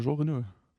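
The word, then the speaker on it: Jean Reno: Wie spricht man den richtig aus, den dritten da? – Äh, Jean Renault.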